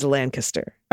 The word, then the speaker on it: Lancaster: Lancaster is Lancaster.